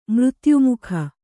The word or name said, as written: ಮೃತ್ಯುಮುಖ